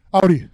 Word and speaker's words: Audi: How do we pronounce it? Let's put it to an end. Give it to him. Give him the mic. How do you pronounce it? – It's Audi for me. – Audi. How about you? Same? Audi for everyone. – Audi. – It's done. The argument's over.